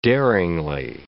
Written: daringly

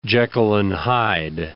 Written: Jekyll and Hyde

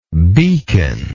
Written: beaconless

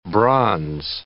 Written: bronze

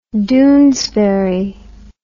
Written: doonesbury

